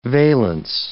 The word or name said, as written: valence